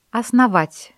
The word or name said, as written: основать